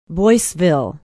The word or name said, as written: boyceville